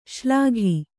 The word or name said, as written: ಶ್ಲಾಘಿ